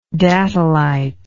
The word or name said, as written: datolitic